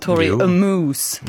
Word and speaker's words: Tori Amos: Hon skrev på sin Instagram, och ville skoja lite om Tori Amos Moose på sin Instagram. Men jag vet inte om jag uttalar det rätt. Hur hade du sagt? Tori Amos. – Jo, det tror jag man kan säga.